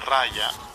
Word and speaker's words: raja: Chakra Estrella Maya chakra estrella de luz, chakra estrella om, chakra estrella raja, se pronuncia raya, término sánscrito, chakra sri ma sati yananda, chakra con corona galáctico, chakra del corazón galáctico.